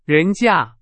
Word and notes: 人家: Chinese

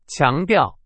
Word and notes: 强调: Chinese

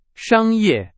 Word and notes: 商业: Chinese

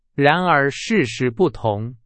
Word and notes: 然而: Chinese

